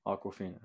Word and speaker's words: Awkwafina: It's Awkwafina, but like with a W and a K. – Aquafina.